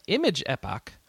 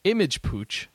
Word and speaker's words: Epoch: But Image Epoch, or as I've been told, Image Pooch, which is my new favorite pronunciation of this name, Image Pooch.